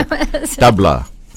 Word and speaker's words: tabla: But for people who don't know that much about Indian music, they're mesmerized by our percussion instrument, which is tabla. And I didn't pronounce that right, did I? – Tabla? I think you did.